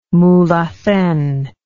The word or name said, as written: Mulhacen